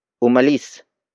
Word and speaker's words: umalis: Umalis na sila doon. Umalis na sila doon. The first word is umalis. Umalis. Umalis. Umalis is spelled as U-M-A-L-I-S.